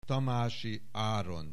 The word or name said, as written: Tamasi Aron